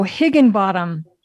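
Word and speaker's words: Higinbotham: Now, I can't pronounce his last name. Is he a German? – Oh, Higginbottom. – Higginbottom.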